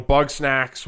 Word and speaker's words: Bugsnax: You guys need to, you know, Bugsnax was good.